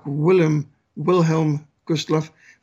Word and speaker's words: Wilhelm Gustloff: And when I say Willem Gustloff, you pronounce it more like Wilhelm Gustloff. But of course, the Germans would have pronounced the W's as a V.